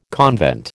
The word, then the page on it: convent: American English